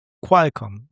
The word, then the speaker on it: Qualcomm: Und Qualcomm wird mit KW ausgesprochen anscheinend. – Qualcomm, ja.